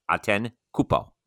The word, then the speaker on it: Antetokounmpo: He either does it the Anglo way or the Greek way or whatever. Like, there's two different ways, but I just pronounced it correctly. I wrote it out phonetically. It's Antetokounmpo.